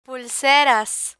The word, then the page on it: pulseras: Spanish